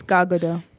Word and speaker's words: Gadot: Is that really how you say your name? – It's Gal Gadot. – Gal Gadot, yeah. – Americanized it, The T is silent.